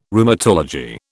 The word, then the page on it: Rheumatology: British English